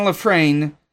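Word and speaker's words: La Frenais: Bennett and Runt worked on the new script but got fired, and were replaced with Dick Clement and Ian LeFrenier. I mispronounced that. Clement and LeFrain wrote a more action-heavy script, but too over the top, too much budget.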